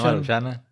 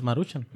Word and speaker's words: Maruchan: Pues que no, tiene, acento, güey. Si tuviera Marushan, Maruchan, acento en la A, güey, ahí sí. Por eso es Maruchan.